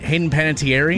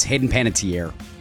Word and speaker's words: Hayden Panettiere: Am I pronouncing her name right? Hayden Panettiere? – No, it's Hayden Panettiere.